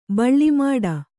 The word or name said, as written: ಬಳ್ಳಿಮಾಡ